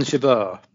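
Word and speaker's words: Jabeur: She went down to, I can't even pronounce her name, but Jaber, Tunisian. – Oh, yeah. – Yeah, she went down to her in the fourth round. – Oh, on Jaber.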